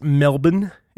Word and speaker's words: Melbourne: If you're from Australia, it's Melbourne is how they pronounce it over there.